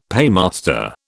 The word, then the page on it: paymaster: British English